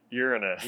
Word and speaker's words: Uranus: conflicting ways to pronounce this planet. – I think right? – the right way is Uranus.